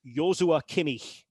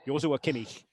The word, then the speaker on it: Joshua Kimmich: And again, his first name is a slightly unusual pronunciation. Even to Germans, they don't necessarily expect it would be pronounced this way. But it is Joshua Kimmich. Joshua Kimmich.